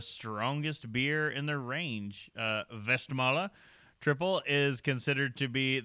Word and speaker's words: Westmalle: So we have a pronunciation, Westmalle, or colloquially Westmalle. Westy if you're from Boston.